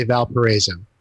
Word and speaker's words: Valparaiso: Well, those of us here in town say Valparaiso. – Valparaiso. Is that Latin? – Well, you know, I think it actually is Spanish. There's a town in Chile called Valparaiso.